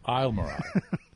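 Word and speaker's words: Islamorada: I know it says that, but it's not pronounced that way. Yeah. It's Isle Morada.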